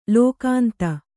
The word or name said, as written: ಲೋಕಾಂತ